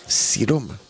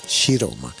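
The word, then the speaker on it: Ciroma: Luca, desde Cosenza, en Calabria, ahí en la radio hermana Radio Chiroma, Chiroma con C, si nosotros en castellano leeríamos Siroma, pues se escribe con C de Cádiz, pero italiano es Chiroma, Radio Chiroma.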